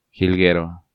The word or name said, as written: Jilguero